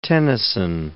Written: Tennyson

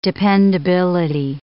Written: dependability

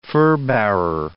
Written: furbearer

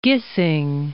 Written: Gissing

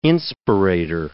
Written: inspirator

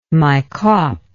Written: Maikop

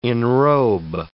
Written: enrobe

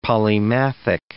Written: polymathic

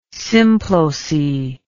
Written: symploce

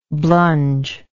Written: blunge

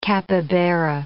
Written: capybara

